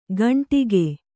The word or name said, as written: ಘಂಟಿಕೆ